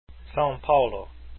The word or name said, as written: saopaulo